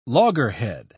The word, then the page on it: loggerhead: British English